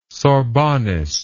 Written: sorbonist

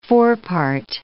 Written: forepart

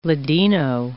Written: ladino

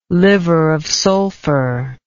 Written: liver of sulfur

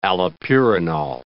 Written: allopurinol

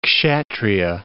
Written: Kshatriya

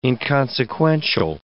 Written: inconsequential

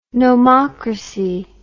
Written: nomocracy

